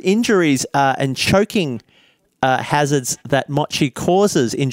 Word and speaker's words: mochi: Let's talk about injuries, and let's talk about I think it's pronounced mochi. Mochi? Mochi. The Japanese dessert.